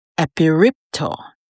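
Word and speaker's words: epiripto (ἐπιρρίπτω): As a matter of fact, in the Greek, the word is pronounced epiripto, E-P-I-R-I-P-T-O.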